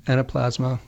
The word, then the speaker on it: Anaplasma: Anaplasma, is that right? Did I pronounce that correctly? – Yes, Anaplasma.